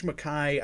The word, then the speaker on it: MacKay: George Mackay, I think that's the right way to pronounce it. It's like George Tukai.